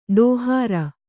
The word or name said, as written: ಡೋಹರ